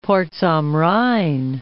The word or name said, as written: Porz am Rhein